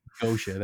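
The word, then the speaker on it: Göetia: I like on my wall right in front of me is like a banner of the Mystifier album Gedia, or however we want to pronounce that album. – Gosha. – And it's like Gosha, that's it.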